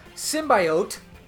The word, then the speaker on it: symbiote: So Venom, the Venom, the symbiote, which why did that lady keep on saying symbiote, symbiote? She said it three times and she said it wrong every time. I'm like, it's just, symbiotics, Symbiote. Symbiote.